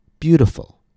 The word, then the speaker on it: beautiful: If I add the suffix full to beauty, the stress stays in the same place. Beautiful, beauty, beautiful, beauty, beautiful. Again, notice how that stress is staying on the same first syllable.